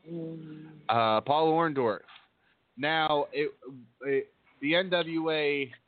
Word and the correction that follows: Orndorff: You said it wrong. It's Orn. Say it like Jesse the Body was at Orndorff.